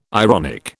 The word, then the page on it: ironic: British English